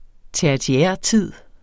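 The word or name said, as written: TERTIÆRTID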